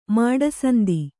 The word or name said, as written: ಮಾಡಸಂದಿ